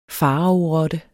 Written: faraorotte

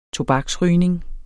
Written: tobaksrygning

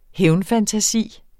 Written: hævnfantasi

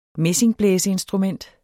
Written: messingblæseinstrument

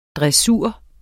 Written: DRESSUREN

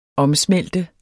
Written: omsmelte

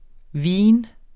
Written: VINENES

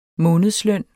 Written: månedsløn

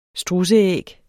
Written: strudseæg

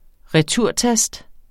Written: returtast

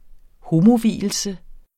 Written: homo

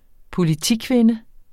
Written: politikvinde

